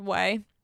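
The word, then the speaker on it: OUAI: O-U-A-I. O-U-A-I, it's pronounced Way.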